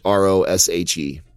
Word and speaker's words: Roshe: These are Nike Roche or Roush, however you want to pronounce it. It's Roshe.